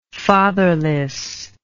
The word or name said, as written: fatherlessness